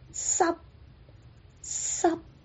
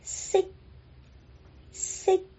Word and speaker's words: 息: 如果闭上嘴巴，这个 C 的粤语就变成了 sub sub，跟湿透干湿的湿一模一样。正确的发音是 sick sick，叹息，叹息，叹息。